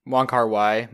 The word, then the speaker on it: Wong Kar-wai: Wong Kar-wai movie.